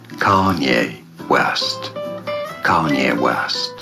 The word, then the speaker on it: Kanye: Kanye. – Kanye, finde ich, sagst du super. – Ich glaube, die Betonung ist auf der ersten Silbe.